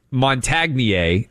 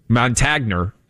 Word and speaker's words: Montagnier: Luc Montagnier, that's the French pronunciation. Montagner, if you're a Southern guy.